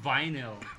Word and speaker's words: vinyl: Ba't ganun pronunciation mo? Vinyl.